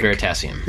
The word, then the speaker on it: Veritasium: Veritasium.